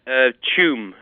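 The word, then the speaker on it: Tuam: From Toom. Is that how you say it? – Choom. I guess it should be spelled C-H-O-O-M, but it's T-U-A-M.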